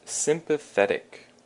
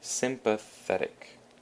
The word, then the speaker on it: sympathetic: And moving on, we have sympathetic. Sympathetic. And sympathetic means 有同情心的, and the emphasis is on the the, the sympathetic.